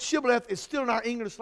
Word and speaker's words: Shibboleth: And they would say to them, say Shibboleth. And they would say, Sibboleth. They could not pronounce the H because of their language.